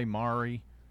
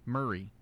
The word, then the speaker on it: Maury: of people want to say Maury, but it's Maury County.